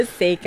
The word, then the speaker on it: Seiko: Wait, sorry, how do you pronounce it? – Seiko. – Seiko. – Yeah. It's okay.